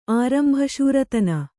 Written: ಆರಂಭಶೂರತನ

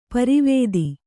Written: ಪಾರಾವತ

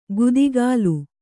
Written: ಗುದಿಗಾಲು

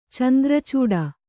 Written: ಚಂದ್ರಾಚೂಡ

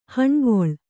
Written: ಹೊಣೆಗೊಳ್

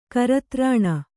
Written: ಕರತ್ರಾಣ

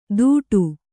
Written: ದೂಡು

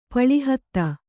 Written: ಪಳಿಹತ್ತ

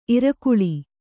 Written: ಇರಕಳಿ

